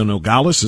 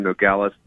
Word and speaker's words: Nogales: I think it's the Nogales is how you pronounce it.